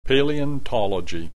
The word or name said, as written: paleontology